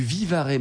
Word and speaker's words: Vivarais: Une vidéo sur Radio, on retrouve maintenant Philippe Forbrac, meilleur sommelier du monde, pour nous parler des côtes du Vivarais.